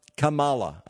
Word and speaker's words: Kamala: Now, I've mentioned about dear old Kamala Harris before, or I prefer to refer to her as Kamala, but Kamala, I believe, is the correct preferred pronunciation.